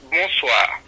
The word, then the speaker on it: bonsoir: Buenas, la tarde se dice bonsoir. Bonsoir. Bonsoir. – Ajá, ah. exacto. B-O-N-S-W-O-U. No, no, W-A, perdón. B-O-N-W-A. Bonsoir.